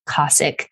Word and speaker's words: Kossek: And her name is Ellen Ernst Kossek.